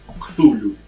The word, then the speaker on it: Cthulhu: El, Chulu. – Eh, hay un montón de formas de pronunciarlo. la más aceptada supuestamente es Cthulhu.